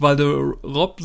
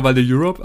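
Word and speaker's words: Val d'Europe: Das ist auch der Running Gag, weil ich jetzt nicht Valderop sage, sondern ich sage immer Valde Europe. Also das ist für mich das Einkaufscenter dort vor Ort. Also je nachdem, wie ihr es aussprechen mögt. Ich versuche es immer so ein bisschen Englisch auszusprechen, aber ist natürlich falsch.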